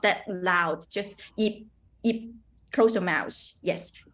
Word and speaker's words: Ip: Because, you know, in Chinese, no matter Mandarin or Cantonese, we pronounce the Y and I is the same pronunciation, it's E. So it's come together, it will be yip. But I have some student, they will have a problem, like they will always say yip.